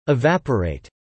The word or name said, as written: evaporate